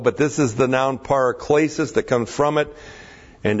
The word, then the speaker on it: paraklesis: By the way, that word in verse 16, God has given us eternal comfort or encouragement, is aionios paraklesis. Aionios, A-I long O-N-I-O-S. Aionios is the word eternal, means eternal. Eternal paraklesis. Looks like this in the Greek. P-A-R-A-K-L long E-S-I-S. Paraklesis.